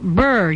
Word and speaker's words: Buhr: Burr, yeah. Well, actually, it's spelled B-U-H-R, but it's going to be cold out there.